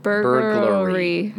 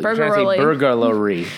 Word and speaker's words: burglary: Burglary. Hey, there you go. – Okay. – You're trying to add an extra syllable. Burglary.